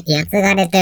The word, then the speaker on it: 僕: 平安時代には、僕と書いて、やつがれと読みました。